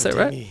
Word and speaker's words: Sabatini: Collaboration with Dino Sabatini, have I pronounced Sabatini. That right? Yeah. – Italian. – Yeah. Roma.